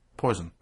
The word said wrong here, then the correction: poisson: I am now aware that it's apparently pronounced poisson.